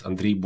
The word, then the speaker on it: Andriy: So my name is Andriy Boychuk. I'm Ukrainian, that's why it's a weird pronunciation, but it's a version of Andrew here in America.